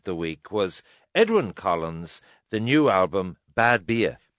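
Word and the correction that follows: Badbea: Bad Bay. – Bad Bay. – Yeah, I was mispronouncing it as well. It's bad. Right. It's a place in Scotland.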